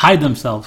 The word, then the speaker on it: themselves: Or you know, maybe celebrities, they want to hide themselves. – No, you don't need another syllable.